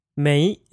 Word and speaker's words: 没: Mei is the other negation word in Mandarin and is spelled M-E-I in pinyin with a second tone.